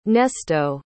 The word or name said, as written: Nesto